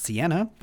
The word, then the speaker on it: Cena: Yeah, John Cena. – I believe it's pronounced Sienna, but I'm also going to give you the point.